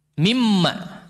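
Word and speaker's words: Mimma (مما): Ada istilah min ma, bagaimana bacanya? Mimma disambung, dia tidak diidghamkan.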